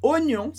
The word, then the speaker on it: oignon: Oi, a gente geralmente pronuncia como wa. Gn, a gente geralmente pronuncia como nha, como em champagne. Mas oignon não segue essas regras. De novo, uma palavra que a gente usa muito. E o que a gente fala, na verdade, é mais ou menos assim.